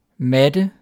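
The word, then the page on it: matte: Danish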